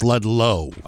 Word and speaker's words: Ludlow: Two men charged in a marijuana grow operation in Ludlow. right? Isn't that how it's pronounced? – No, it's Ludlow.